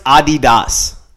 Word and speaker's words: Adidas: He got dropped from fucking Adidas. – Yeah. He got dropped from Gap. Got dropped. – Suppose it's pronounced Adidas. Everywhere else in the world. – Adidas.